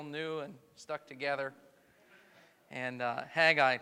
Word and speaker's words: Haggai: And the book of Haggai, some call it Haggai. It's okay, everyone has a right to be wrong. Haggai is the phonetic pronunciation, we'll go with that today. You can pronounce it differently.